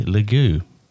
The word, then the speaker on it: Lague: Today, it's my intention to interview Richie Lague. Correct pronunciation? That is, yeah.